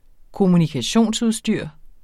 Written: kommunikationsudstyr

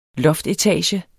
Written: loftetage

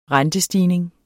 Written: rentestigning

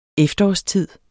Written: efterårstid

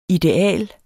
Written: ideal

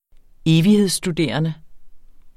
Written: evighedsstuderende

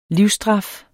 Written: livsstraf

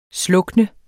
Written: SLUKNES

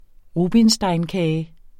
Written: rubinsteinkage

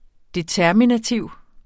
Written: determinativ

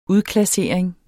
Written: udklassering

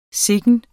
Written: sikken

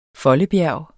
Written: foldebjerg

